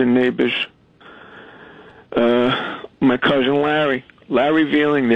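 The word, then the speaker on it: Veiling: His name is Larry Veeling. He's a firefighter. We never found him. His last name is spelled V-E-I-L-I-N-G. V-I-E-L-I-N-G, Veeling, and they've never pronounced it right.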